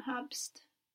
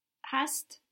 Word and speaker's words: hast: Du hab, it's not habst, hast. – Very good. Du hast.